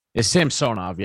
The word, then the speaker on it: Samsonov: When you look at the overall picture of what they've done, having added both Matt Murray and Ilya Samsonov, or Samsonov, how do you pronounce it? – It's Samsonov, yeah.